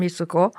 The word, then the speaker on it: Mitsuko: And I was still using my given Japanese name, which is Mitsuko. It was always, they mispronounced it anyway. It was always Mitsuko or Mitsuko instead of Mitsuko.